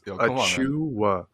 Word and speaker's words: Achiuwa: Yeah, for any Raptors fans that don't know how to pronounce it, I'm sure you guys know how to pronounce Precious. It's Achiuwa. – Nigerian names are phonetic. Just look at it, take a slow, and then just say it. But it's phonetic in English.